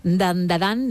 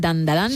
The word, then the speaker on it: Dandadan: Tenemos Dandadan, Dan, creo que lo he dicho bien, Dan Dadan. – Sí, Dan Dadan.